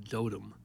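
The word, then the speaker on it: Dotum: They pronounce it like Dotem, I think, something like that.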